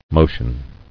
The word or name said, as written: Motioning